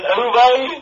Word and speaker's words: Uwe: Uwe Boll? – Uwe Boll, oh God. – Is it Uwe? – Yeah, because it's a German name.